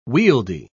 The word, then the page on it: wieldy: British English